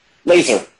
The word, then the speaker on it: Lazer: So your main character, Lazar, how would you describe Lazar? Like, who is Lazar? – Laser. So Laser, sorry. – Yeah. – That's how they say it in Canada.